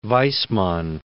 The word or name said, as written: Weismann